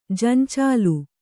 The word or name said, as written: ಜಂಚಾಲು